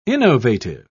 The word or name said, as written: innovative